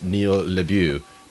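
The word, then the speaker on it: Neil LaBute: I believe his name is pronounced Neil LeBue. But it's like spelled like Neil the Butt.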